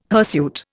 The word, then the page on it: hirsute: Indian